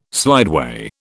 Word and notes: slideway: British English

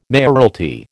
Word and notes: mayoralty: American English

